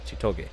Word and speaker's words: Chitoge: She is the bodyguard to Chitose, and my god, Chitoge. I keep getting her name wrong. Yes, Chitoge.